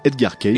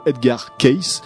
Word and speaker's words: Edgar Cayce: aujourd'hui, il y a un mythe autour de ce Edgar Case.